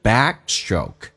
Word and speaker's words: backstroke: back 的 k 是不用发音，因为两个辅音只用发后面一个，同时 stroke 那个 tr 要浊化成 dr，就变成 backstroke，气流阻塞一下， backstroke.